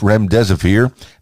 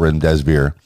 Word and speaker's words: remdesivir: Number one, the FDA approves emergency use of remdesivir. Now, I hope I'm pronouncing that right. Remdesivir, R-E-M-D-E-S-I-V-I-R.